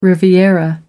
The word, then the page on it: riviera: American English